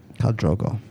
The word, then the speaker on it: Khal Drogo: I went on the interwebs and Googled it, and there's like a video where they pronounce it, and it's Khal Drogo. Khal Drogo.